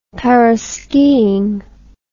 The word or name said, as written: paraskiing